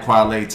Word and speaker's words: Kweli: That's It's spelled like Kwale Talib. So when you think of Kwale Talib, think of Queely TV.